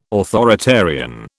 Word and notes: authoritarian: British English

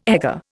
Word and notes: egger: Indian

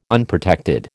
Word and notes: Unprotected: American English

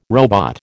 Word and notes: robot: American English

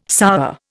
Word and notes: sour: Indian

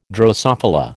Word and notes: drosophila: American English

